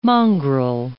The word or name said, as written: mongrel